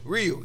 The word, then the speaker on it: Ryu: Rui? – Ryu, yeah.